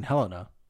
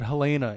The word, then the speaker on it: Helena: I used to think it was Boxing Helena, but they do pronounce it Helena in the film.